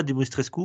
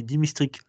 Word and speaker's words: Dimitrescu: Euh, tiens, euh, euh, pour revenir sur notre Dimitrescu alors déjà, on ne dit pas Dimitrescu on dit Dimistric. Le U final ne se prononce pas.